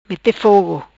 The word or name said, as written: Mete fogo